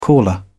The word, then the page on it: caller: British English